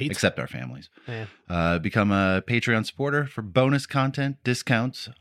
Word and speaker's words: Patreon: But I'm pretty sure it's Patreon because it's like a patron.